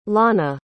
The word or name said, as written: launa